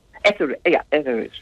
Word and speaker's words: Etheridge: Etheridge, ja.